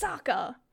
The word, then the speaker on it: Sokka: And I'm like, no, it's Sokka. – And I'm like, bro, did he even say Sokka this time? – I know.